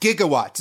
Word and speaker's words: gigawatts: While we're pointing out arbitrary things that are wrong with the movie, it's pronounced gigawatts. It's 1.21 gigawatts, but Robert Zemeckis and Bob Gale, at one point during the screenwriting process, consulted with like a science professor that they knew, and he pronounced it gigawatts, and they just took him at his word. And so they corrected Christopher Lloyd. We're like, no, it's gigawatts.